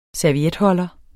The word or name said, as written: servietholder